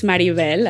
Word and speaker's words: Maribel: So my name is Maribel, as you all now know, but people in the United States wanted to pronounce it Maribel or Maribel, which is nothing near what I want to be called.